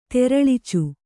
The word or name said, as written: ತೆರಳ್ಚು